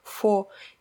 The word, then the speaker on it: faux: Оно пишется F-A-U-X, и, в принципе, людям, которые знают французский язык, наверное, оно не покажется каким-то странным, потому что во французском оно звучит «фо», и точно так же оно звучит и в английском.